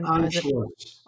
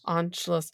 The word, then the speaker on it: Anschluss: Do you want to say it? On Anschluss. – Anschluss.